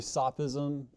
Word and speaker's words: sophism: It's really sophism and sophistry. I was kind of corrected.